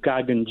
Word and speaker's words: Gagan: Do you pronounce your name Gagnon, or am I saying that wrong? – Yeah. Hey, Mocha, this is Gagan, G-A-G-A-N.